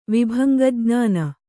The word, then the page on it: ವಿಭಂಗಜ್ಞಾನ: Kannada